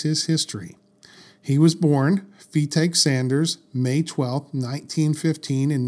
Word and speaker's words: Fetaque: So, now we come to Fetaque Sanders, and it's pronounced Fetaque Sanders, not Fetacue. like I thought. Sorry, I just that's what I thought it was. I was like, that can't be right. No, it's take.